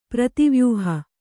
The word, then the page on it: ಪ್ರತಿವ್ಯೂಹ: kannada